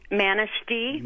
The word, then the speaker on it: Manistee: And a quick question, Manistee, am I pronouncing that right? – Manistee.